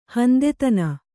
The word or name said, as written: ಹೊಂತತನ